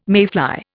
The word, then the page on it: mayfly: Indian